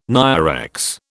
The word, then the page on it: NIREX: British English